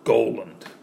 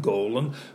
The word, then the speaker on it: Goland: A nom du café, nom du Uber, when you call in or text in to get a reservation in a restaurant and they have trouble pronouncing your name, Goland. That's my last name, Goland. It becomes Gulen. How do you spell it? G-U-G-L-G-D, Goland, Goland.